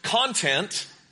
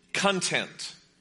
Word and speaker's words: content: It could be pronounced content or content. It depends where you emphasize the syllable.